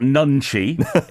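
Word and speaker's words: 눈치: Because it's N-U-N-C-H-I on the title. – Yeah, it's not Nunchi. It's like I would spell it N-O-O-N-C-H-I. Nunchi.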